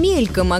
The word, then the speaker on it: мельком: Мельком или мельком, как правильно? Известно, что по стихам ударение проверять не стоит. Поэты – люди творческие, для рифмы вполне могут поставить любое ударение. Но Симонов в обоих случаях против правил не погрешил. Большинство словарей считают варианты «мельком» и «мельком» равноправными, и лишь очень немногие относят вариант с ударением на втором слоге «мельком» к разговорной речи.